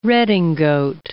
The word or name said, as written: redingote